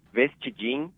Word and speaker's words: Vestidinho: Vestidinho, the pronunciation in Portuguese is Vestidinho, which means small dress. – Small dress, okay. – Yeah, it's in Portuguese you say Vestidinho, but in the north, people has an accent, and they say just Vestidinho, without a Vestidinho.